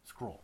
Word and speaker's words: Scurll: Scurll? Is that how you say it? Scurll? I don't even know who this guy is, actually. Scurly? Scurll. Marty Scurll. Yeah.